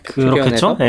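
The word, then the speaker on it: Patreon: 또 이승규 님이 패트리온이라고 읽는 거 맞죠? 이거 예. 네, 패트리온에서 네.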